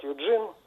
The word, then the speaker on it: Юджин: Вот, имени, например, вот мое имя на английский переводится как Юджин, Евгений, и я никогда от носителей языка английского не встречал ударения на первом слоге. Вот всегда только на втором, несмотря на то, что вот иногда в словарях два ударения дается.